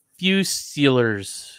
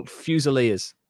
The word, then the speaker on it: Fusiliers: Fusiliers. – Oh, Fusiliers, yes.